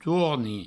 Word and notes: torni: touarni

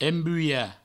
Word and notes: embulhar: embuia

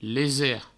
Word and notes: leser: provençal; lese

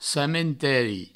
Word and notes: camenteri: provençal

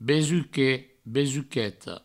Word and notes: besuquet, besuqueta: Provençal; besuquet, besuqueto

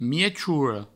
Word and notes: miechora: miechouro

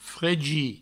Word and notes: fregir: fregi

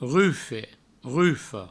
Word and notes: rufe, rufa: rufe, rufo

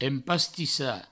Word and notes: empastissar: empastissa

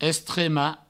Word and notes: estremar: Provençal; estrema

